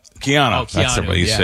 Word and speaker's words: Keanu Reeves: I think of Canal Reeves, the Matrix guy, Canal Reeves, right? Am I saying his name right? Canal Reeves? – Keanu Reeves.